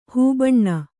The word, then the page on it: ಹೂಬಣ್ಣ: hū baṇṇa